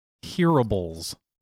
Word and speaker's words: hearables: The new word is hearables. – Hearables? – It's spelled like wearables, but with an H instead of a W.